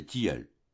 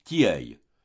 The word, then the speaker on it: tilleuls: Le long de chaque muraille règne une étroite vallée qui mène à un couvert de tilleuls, mot que Madame Vauquer, quoique née de Conflans, prononce obstinément « tilleuil », malgré les observations grammaticales de ses hôtes.